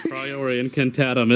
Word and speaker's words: Priori Incantatum: Right, because if there's a way to counteract the Puri Incantatum or whatever that spell is called. – Well, smart Voldemort would change wands, but this is Voldemort we're talking about. – Yeah. – Priori Incantatum is the spell that makes you see your dead parents, right? – That's right.